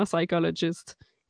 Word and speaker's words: psychologist: C'est juste un truc mnémotechnique pour que tu te rappelles à vie que tu prononces pas le P dans psychologist.